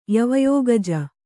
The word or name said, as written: ಯವಯೋಗಜ